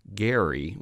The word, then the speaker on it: Gerry: But I learned through your book that the guy who it's named after, Elbridge Gerry, actually pronounced his name Elbridge Gerry with a hard G.